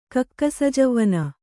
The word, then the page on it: ಕಕ್ಕಸಜವ್ವನ: kakkasajavvana